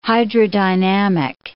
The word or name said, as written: hydrodynamic